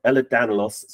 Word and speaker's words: Ehlers-Danlos: We have Sarah Hamilton, who is the Information and Advice Manager at the, I'm going to say it right, Ehlers-Danlos Support UK charity. Did I say that right, Sarah? – You did. You nailed it.